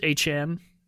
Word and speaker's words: Etienne: We did see him play second fiddle to Travis Etienne, as we now refer to him as, because that is how his name is pronounced.